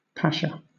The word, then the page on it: pasha: British English